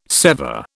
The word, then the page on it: sever: British English